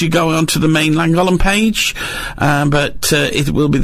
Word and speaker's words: Llangollen: And Llangollen 2022.